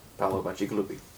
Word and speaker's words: Paolo Bacigalupi: Paolo Bacigalupi.